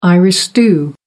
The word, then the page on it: irish stew: American English